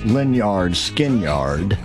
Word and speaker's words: Lynyrd Skynyrd: So of course, old Lynyrd Skynyrd, pronounced Lynyrd Skynyrd, and that is Simple Man.